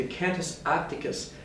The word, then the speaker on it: Cantus Arcticus: The Cantus Arcticus, let me pronounce that correctly, the Cantus Arcticus is a concerto for birds and orchestra.